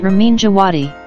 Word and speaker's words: Ramin Djawadi: Mas seguinte, o Davi também ele mandou outra pergunta, que é Ramin Djawad, desculpa se eu errei, eu, minha pronúncia indiana tá meio complicada complicada. Sabe falar perfeitamente considerado... o nome dele. – Falei. – Ramin Djawadi.